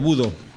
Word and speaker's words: Bodø: Bodø es la... así se pronuncia, Bodø.